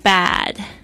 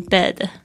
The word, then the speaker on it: bad: 那还有就是这个 bad B-A-D, 注意一下那个 A 的声音呢，要小心一下，不要念成 bed, 好像嘴巴都不愿意睁大， bed 就变成那个床 bad 就是好像就是去看那个牙齿的时候，那个不是会有这个压舌板吗？